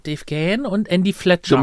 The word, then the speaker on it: Gahan: Wusstest du eigentlich, dass Dave Gahan Dave Gahan ausgesprochen wird? – Ich sag Dave Gahan.